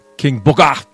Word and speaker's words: King Booker: And you mispronounced his name again. – King Booger? – King Booker!